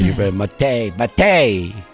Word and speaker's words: yerba mate: Yerba mate. – Even though it's spelled mate.